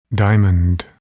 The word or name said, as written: diamond